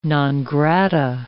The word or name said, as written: non grata